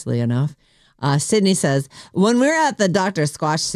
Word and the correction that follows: Squatch: shoot, sorry, I mispronounced that. Let me try again. I was so excited about it. When we were at the Dr. Squash suit. – What? – Dr. Squatch.